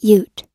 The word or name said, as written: ute